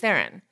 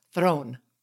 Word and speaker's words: Theron: Preceding Jennifer Lawrence's Dior campaign is Charlize Theron. So, or Charlize Throne. That's how is the correct pronunciation. Throne.